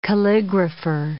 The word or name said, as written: calligrapher